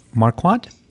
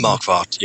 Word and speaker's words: Marquardt: And the other one by Klaus Marquardt. Did I say that right? – Marquardt, yeah.